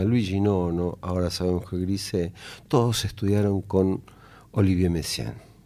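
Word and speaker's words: Grisey: Grise, cuyo nombre se escribe Grisei con I griega al final, nació en Belfort en 1946, en Francia.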